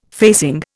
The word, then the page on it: facing: Indian